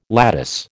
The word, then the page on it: lattice: American English